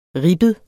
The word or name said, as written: ribbet